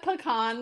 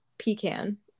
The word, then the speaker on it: pecan: I'm gonna go with pecan this time, but I immediately tasted the pomegranate and the pecan. – Except it's still wrong. It's pecan.